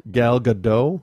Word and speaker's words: Gadot: Gal Gadot? – Yeah.